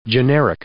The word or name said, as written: generic